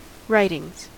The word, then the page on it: writings: American English